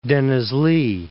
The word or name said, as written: Denizli